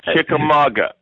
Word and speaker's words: Chickamauga: Last couple weeks ago when we were at Chickamauga, you got Chickamauga, by the way. I want to stop you right there. It is Chickamauga.